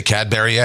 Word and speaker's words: Cadbury: C-A-D-B-U-R-Y. – Cadbury? – Oh, no. Cadbury. – We always say Cadbury eggs, right? But the correct pronunciation is actually Cadbury. – Cadbury. Westbury. – Not no B and no Uri in there. Cadbury. – Like it's B-R-E-Y something.